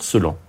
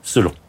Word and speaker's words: Celan: Celan. Dans la graphie roumaine, si on écrit, ça s'écrirait en selle, et c'est à partir de là qu'est forgé le nom Celan.